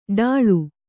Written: ಡಾಳು

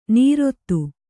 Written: ನೀರೊತ್ತು

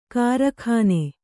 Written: ಕಾರಕೂನ

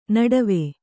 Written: ನಡುವು

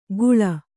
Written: ಗುಳ

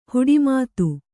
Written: ಹಡೆಮಾತು

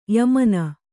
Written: ಯಮನ

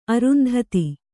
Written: ಅರುಂಧತಿ

